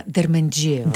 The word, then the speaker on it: Дерменджиева: Да, Дерменджиева. – Дерменджиева. – Да ударим ударението, да. Правилно.